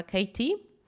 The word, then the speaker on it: Khaite: Jest taka marka nowojorska Katie. E, pisze się ją K-H-A-I-T-E.